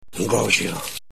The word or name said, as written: engorgio